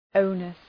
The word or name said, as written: onus